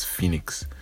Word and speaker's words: Phoenix: I was pronouncing it wrong, and then he told me it's Phoenix, and then kind of explained what it was.